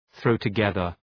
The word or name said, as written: throw together